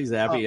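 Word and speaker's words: Zappe: I think I pronounced the name Zappy, right? So Bailey Zappy, yep.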